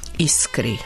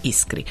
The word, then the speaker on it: Iskri: U istom smeru je orijentisan program studije zajedničkog koji je počeo u ponedeljak u Beogradskoj Novoj Istri. Iskri, hoću li više izgovoriti Novoj Iskri.